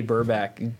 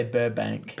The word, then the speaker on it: Burback: Every time I go to say his name, I gotta say Burbank. – Burbank. – So do I. – It's Burback.